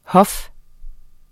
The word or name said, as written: HOFS